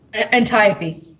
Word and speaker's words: Antiope: is it Antiope? Is that how you pronounce it? – Antiope.